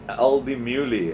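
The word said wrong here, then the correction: Al Di Meola: I'm pronouncing it right. – Aldi Meola. – Aldi Meola, your favorite guitarist.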